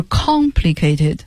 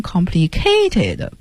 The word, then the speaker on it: complicated: complicated 就是很复杂的啊，就注意大家这个单词的读音，它的重读音节在第一个音节上是 complicated。因为我教学生的过程中老听见有同学把它发音成 complicated，不对，重音不在 k 上面，是在 con 上面，复杂的形容词。